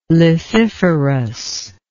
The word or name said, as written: lethiferous